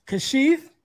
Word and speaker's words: Kesheaf: And also we have my man up here too, and I hope I pronounce it right, Kesheaf.